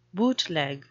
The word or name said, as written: bootleg